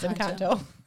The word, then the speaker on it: Encanto: Is that how you say it? It's not Encanto? – No. It is Encanto.